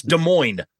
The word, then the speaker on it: Des Moines: both say the silent S in Des Moines, because it's Des Moines. They're like, all right, Des Moines. No. It's Des Moines. It's not plural. The S is silent.